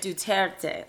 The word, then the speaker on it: Duterte: Is it Duterte? – Duterte.